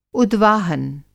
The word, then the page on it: उद्वाहन: Hindi